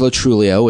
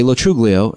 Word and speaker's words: Lo Truglio: The correct pronunciation is Lo Truglio, which I found, which I started using in my 20s. But my family, most friends say Lo Truglio, and I think both.